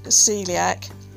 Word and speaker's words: coeliac: This year, CUK, that's Celiac UK, it's spelt C-O-E-L-I-A-C, but it's pronounced celiac.